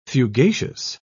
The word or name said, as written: fugacious